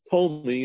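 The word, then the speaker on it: Ptolemy: In English, it's Tolmi, you know, but you spell with a P, but you don't pronounce the P.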